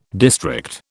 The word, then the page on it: district: British English